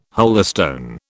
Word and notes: holystone: British English

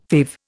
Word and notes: fave: Indian

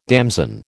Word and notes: damson: American English

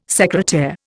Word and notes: secretaire: Indian

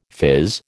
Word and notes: phiz: American English

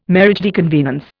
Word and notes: mariage de convenance: Indian

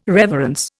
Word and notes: reverence: Indian

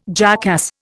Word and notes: jackass: Indian

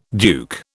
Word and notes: duke: British English

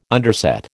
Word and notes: underset: American English

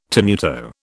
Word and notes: tenuto: British English

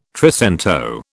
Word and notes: trecento: British English

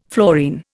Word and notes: fluorine: Indian

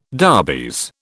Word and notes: darbies: British English

